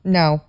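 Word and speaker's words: Ngo: How do you say his last name? – No.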